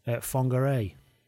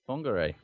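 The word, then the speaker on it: Whangārei: But first of all, I'll give my apologies for my terrible pronunciation of what should be pronounced, I've been informed, Fongaray. – Fongaray.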